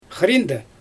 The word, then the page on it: xriinde: Xyzyl